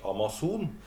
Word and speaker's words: Amazon: Og, og da blir det jo, når vi uttaler det på norsk, Amazon, ja, så blir det plutselig veldig mye mer nært.